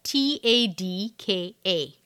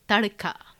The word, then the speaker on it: Tadka: Okay, Tarka is T A D K A. Tarka. So, where's the R sound come from? Tarka. – Tarka. Tadka. Tadka. Tadka. Yeah. – But it's spelled Tadka. It's the D. Sometimes the D has a nice little er thinging happening.